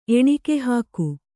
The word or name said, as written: ಎಣಿಕೆಹಾಕು